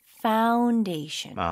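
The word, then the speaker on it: foundation: 무조건 F 발음으로 입술이 붙으면 안 돼요. 파운이 아니고 파운데이션.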